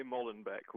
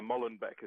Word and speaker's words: Molenbeek: One area you talked about going to was in Brussels, and the actual name of the neighborhood is Molenbeek? Is that the right pronunciation? – They say Molenbeek. We say all sorts of things because we can't pronounce it. But yeah, Molenbeek is how they would say it.